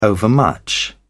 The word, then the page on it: overmuch: British English